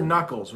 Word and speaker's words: knuckles: Those are knuckles right there. Those things. Those are your knuckles. It's a silent K. We don't produce the K there.